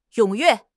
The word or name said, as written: 踊跃